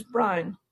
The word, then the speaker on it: Brown: B-R-O-W-N, like the color. But when I say it's Brown over the phone, everybody thinks it's Brian.